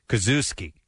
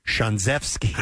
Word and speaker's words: Ksiazewski: It says, It's spelled K-S- I-A-Z-E-W-S-K-I. – Jesus. – He pronounces it Kazuski, okay, or Kazuski, somehow or another like that. But in Polish, it's pronounced Szanzewski.